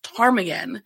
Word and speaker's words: Ptarmigan: I remember one of my early calls at my new agency, we have a street in the city that's pronounced Ptarmigan, and I kept spelling it with a T.